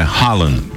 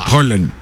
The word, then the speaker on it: Haaland: E não é Haaland. – A pronúncia? – Rola. – Haaland. – Nós escandinavos. – Certo, você é de lá. – Nós escandinavos. É o seguinte: toda vez que você vê dois As juntos, você fala O.